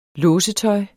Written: låsetøj

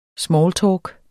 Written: smalltalk

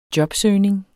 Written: jobsøgning

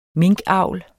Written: minkavl

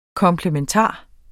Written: komplementar

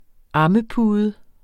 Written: ammepude

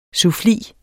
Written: suffli

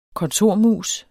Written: kontormus

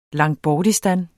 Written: Langtbortistan

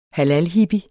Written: halalhippie

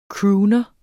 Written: crooner

